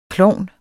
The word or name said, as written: klovn